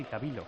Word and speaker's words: Tabilo: 2 sets a Tabilo, el chileno, que creo que se dice así, Tabilo. – Tabilo es esto.